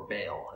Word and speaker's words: Baal: where Elijah fought the prophets of Baal, or Baal, as some people pronounce it, 400 of these prophets.